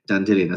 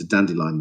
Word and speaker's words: Dandelion: And I thought actually that the fact that he would deliberately say his name Dandelion as opposed to Dandelion the flower would be very indicative of his character, a bit like the character of Hyacinth Bucket in Keeping Up Appearances.